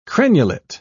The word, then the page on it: crenulate: British English